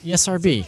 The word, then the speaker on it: ESRB: First of all, do you pronounce it ESRB or ISRB? – ESRB. – ESRB. Do you say ISRB? – Don't listen to this guy.